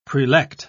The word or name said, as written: prelect